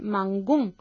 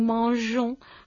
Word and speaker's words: mangeons: tendríamos que pronunciar mangón, y en realidad entonces nos alejaríamos del infinitivo, de la pronunciación manger, de manera que para que siga sonando nous mangeons hay que poner una E entre la G y la desinencia ONS.